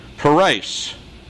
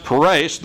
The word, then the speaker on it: peres: Daniel pronounces the word in verse 28, peres. Peres. Pronounced peres, the word is a noun and it means a half mina.